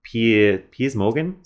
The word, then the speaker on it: Piers Morgan: In particular, he went on Piers Morgan, is that how you say it? Yeah,